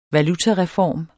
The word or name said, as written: valutareform